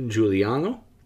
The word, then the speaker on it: Giuliano: Uh, the last film we saw from him was Salvatore Giuliano, the sort of investigation of the slain Sicilian Bandit.